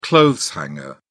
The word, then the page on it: clothes hanger: British English